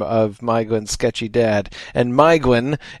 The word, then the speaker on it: Maeglin: that is Maedhros and Maeglin, both of which should be I and not A.